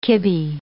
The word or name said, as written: kibbe